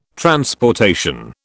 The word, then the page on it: transportation: British English